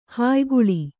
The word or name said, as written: ಹಾಯಿಗೊಳ್